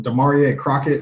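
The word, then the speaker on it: Damarea Crockett: I know they went out and signed guys like Caron Higdon and, gosh, what's it, Demarie Crockett. Am I saying that right, Ben? – Mm-hmm.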